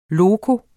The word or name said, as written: loco